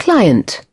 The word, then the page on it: client: British English